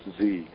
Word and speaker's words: Xe: Things like Halliburton and Blackwater, who's now called XE or pronounced Z.